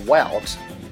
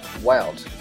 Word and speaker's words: Welt: And in German, we say Welt. Welt. W E L T.